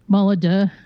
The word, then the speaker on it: Molyneux: Molyneux.